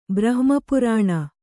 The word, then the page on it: ಬ್ರಹ್ಮಪುರಾಣ: kannada